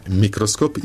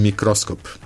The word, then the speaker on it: mikroskop: Dozvoljeno je reći mikroskop i mikroskop.